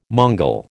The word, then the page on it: Mongol: American English